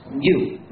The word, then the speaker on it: gnu: Parce que selon le dictionnaire, la G est muette et le mot... Et le mot se prononce new, comme nouveau.